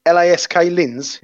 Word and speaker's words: LASK Linz: LASK Linz.